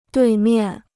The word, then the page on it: 对面: duì miàn